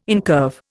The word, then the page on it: incurve: Indian